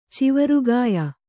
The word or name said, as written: ಚಿವರುಗಾಯ